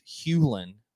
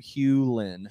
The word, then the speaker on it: Hulan: My last name is pronounced Hewlin. Hugh, like the color, and Lynn, like a girl's middle name. Hewlin.